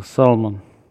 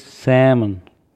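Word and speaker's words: salmon: Салмон. Почему, не знаю. Хотя я уже когда-то говорил, что буква «Л» не читается. И правильно говорить не «салмон», а правильно говорить «сэмон».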